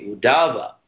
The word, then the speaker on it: Uddhava: Why is Uddhava pronounced as if there is a line over the A? Uddhava.